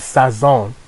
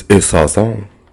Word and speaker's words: saison: The word you're trying to pronounce is saison. Saison. That's the season you try to pronounce.